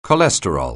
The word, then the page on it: cholesterol: anglais